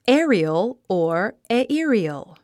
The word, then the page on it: aerial: é ə riəl; エリヨゥ